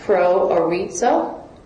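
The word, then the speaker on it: proorizo: And the Greek word is also really interesting there too, because it's pronounced pro-orizo.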